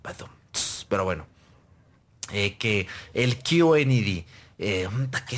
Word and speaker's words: QLED: Que aquí decimos QLED. O que se ve bien culedo. Ah, pero no, se pronuncia QLED.